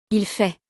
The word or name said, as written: il fait